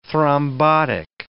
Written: thrombotic

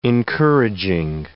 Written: encouraging

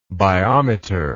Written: biometer

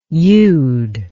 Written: youd

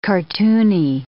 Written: cartoony

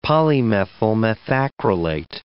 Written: polymethyl methacrylate

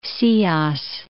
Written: Ceos

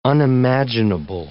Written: unimaginable

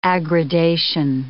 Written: aggradation

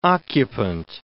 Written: occupant

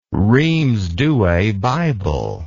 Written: rheims douay bible

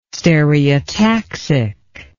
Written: stereotaxic

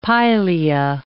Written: pilea

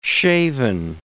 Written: shaven

